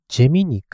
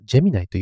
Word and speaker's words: Gemini: これでジェミニというふうに呼んでるんですけど、えっと、二つありまして、ジェミニかジェミナイ。という呼び方があります。海外、英語の発音では、これあの、 GEMINI はジェミナイというふうに呼ぶんですね。